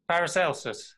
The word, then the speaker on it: Paracelsus: I want you to tell me more about the story of Paracelsus. Is that how you say his name? – Oh, yeah, that's near enough anyway, yeah. Yes, Paracelsus.